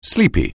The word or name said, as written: sleepy